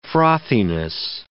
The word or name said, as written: frothiness